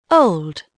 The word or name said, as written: old